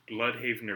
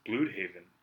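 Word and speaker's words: Blüdhaven: It's either Bloodhaven or Bloodhaven. – Bloodhaven? – B-L-U with an umlaut, D-H-A-V-E-N. – I mean, I think the umlaut says it all. – It says it's Bloodhaven, right? – Yeah.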